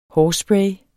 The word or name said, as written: hårspray